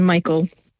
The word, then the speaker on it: Mykel: Yeah, it's Kathryn Mykel.